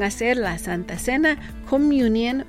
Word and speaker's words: communion: La primera lo pueden llamar así communion. Communion. Y se escribe C-O-M-M-U-N-I-O-N. Una vez más, se escribe de esta manera: c o m m u n i o -N, Communion,